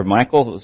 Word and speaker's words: Michal: The name of the firstborn was Merah, and the name of the second one was Michal, or Michael, some pronounce it.